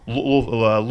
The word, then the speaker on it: Louisville: How do you say it properly, actually? – Louisville. – I'll take your word for it. – If you're from Cincinnati, you pronounce it Louisville. – Louisville. Okay. Yes.